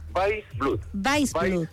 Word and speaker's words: Weissblut: Weissblut, tal como se escribe.